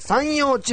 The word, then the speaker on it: 山陽: 近畿圏はですね、まあ大体、午後の最高気温が 10 度前後を記録する日が続いているんですけれども、はい、山に太陽の陽と書いて山陽地方と読む広島県にお住まいのこの方、地元の気候はいかがなもんでしょうか。